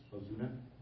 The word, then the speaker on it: Ozuna: Ozuna. – Well, I'm sorry. Could you spell that? – O-Z-U-N-A.